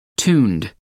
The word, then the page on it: tuned: American English